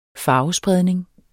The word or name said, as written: farvespredning